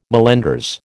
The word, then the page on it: mallenders: American English